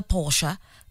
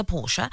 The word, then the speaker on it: Porsche: Baba Gatando says, Lerato, it's a Porsche. Correct pronunciation, not very common, but it's a Porsche.